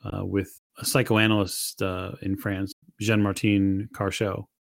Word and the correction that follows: Charcot: Charcot. Sorry, I probably butchered that for our French listeners.